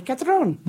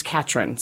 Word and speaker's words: Catron: I've been practicing because living in Mexico for so long, you want to say Catron, but it's Katrin.